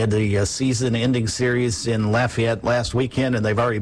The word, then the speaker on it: Lafayette: It's Lafayette, Louisiana.